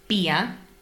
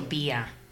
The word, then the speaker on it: beer: Well, we have bia, which is your classic cruzcampo. Ooh. That's your bia. – Bia. Here. Near. Ear.